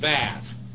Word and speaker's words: vav: And again, the shawa or the wow that the Hebrew, if you deal with Jewish people very much, the wow looks like that. A lot of the Jewish people pronounce it vav.